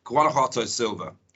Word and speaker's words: Guanajuato: Yeah, I mean, one of our favourites at the moment, and it's one of our favourites really, because I can never pronounce it, but I'll give it a go. But it's Guanajuato Silver. How about that? I think I got it right, didn't I, Ollie? – Spot on.